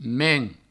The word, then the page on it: tenirdament: tenidament